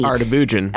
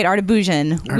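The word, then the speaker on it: Erdoğan: Also, we all pronounce Erdogan's name correctly. – Artabujan. – That's right. Artabujan.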